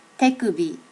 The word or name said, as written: tekubi